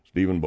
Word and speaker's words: Steven: My name is Stephen Bozell. That's spelled S-T-E-V-E-N.